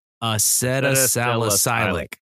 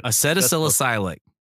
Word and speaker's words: acetylsalicylic: Now one of the most common drugs in household medicine cabinets, acetylsalicylic acid. Oh my gosh, that's so hard to say. I even typed it out phonetically. It's really hard to say. – Acetylsalicylic. That's it. – Acetylsalicylic.